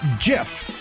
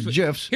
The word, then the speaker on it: GIF: Is it GIF with a hard G or GIF? Well, GIF the peanut maker, that would be J-I-F, not G-I-F, wants to put a stop to all the arguments by teaming up with Jiffy.